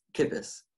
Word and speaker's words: Kippes: Did I say that right? – It's Kippes. – Kippes? Okay, Austin Kippes.